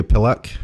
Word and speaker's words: pillock: Ick. Pillock. Not ock. Ick. Yes.